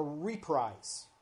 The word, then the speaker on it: reprise: and there will be something called an R-E-P-R-I-S-E. Anybody ever seen that word? Okay. I always thought that was a reprise. It's actually pronounced reprise.